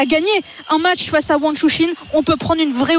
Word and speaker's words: Wang Shuxin: T'as vu comme elle prononce ça, ah oui, Wang Shuxin. – Wang Shuxin. Ouais. Alors que ça s'écrit Wang Shuxin. Moi, bêtement, j'ai dit Shuxin, mais non, c'est pas ça. C'est pas Shuxin, c'est Shuxin.